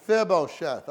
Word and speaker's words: Mephibosheth: Mephibosheth. – Say it one more time. – Mephibosheth. – Mephibosheth. I don't know how long it took me to learn to say that name. Mephibosheth.